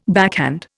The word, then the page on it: backhand: Indian